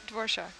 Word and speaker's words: Dvorak: How do you pronounce it? – Dvorak.